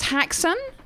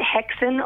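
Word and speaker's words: Häxan: Your new single, is it pronounced Haxan? – Yeah, absolutely. You can pronounce it Hexen or Haxan. – So, it's Scandinavian for witches.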